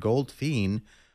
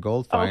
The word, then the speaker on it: Goldfein: Speaking of which, thank you, Lisa, for waiting till almost the end of the program to remind me that it's pronounced General David Goldfein rather than Goldfein.